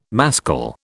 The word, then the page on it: mascle: British English